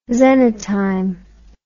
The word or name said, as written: xenotime